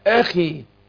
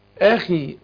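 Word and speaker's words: أخي: فعشان كده بقول وهذا اخي، اخي ما اقولش اخي اخي زي كتير ما بينطقوها، او مثلا يقول ان اخي له 99 نعجة، ان اخي وده خطأ، اخي دي اقول ان اخي اخي مش اخي. هنا برضو ايه قال وهذا اخي، انا بكررها ليه؟ لان كتير بينطقوها زي ما انا قلت خطأ كده، يقولوا ايه اخي، فالهمزة في الاصل مرققة يروح مفخمة، والخاء هنا تاخد ادنى درجات التفخيم يروح من ده تفخيم ايه عالي.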